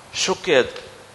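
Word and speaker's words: шокед (שֹׁקֵד): Слово «миндаль» «шакед» и слово «бодрствовать» или «контролировать» «шокед» выглядит одинаково в иврите. Одинаково. Здесь пишется «шакед», а здесь «шокед».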